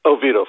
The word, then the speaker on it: Oviedo: Is it Odio, Florida? – Oviedo, Florida. – Oviedo. Okay, it says Odio.